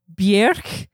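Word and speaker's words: Björk: The correct way to pronounce her name is Bjerk. – Bjerk. – Guðmundsdóttir? – Bjerk. – Bjerk. – Bjerk. – It's Bjerk, like, I don't know what rhymes with it. – Jerk? – Bjerk like jerk, not Bjork like stork.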